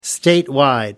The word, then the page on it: statewide: American English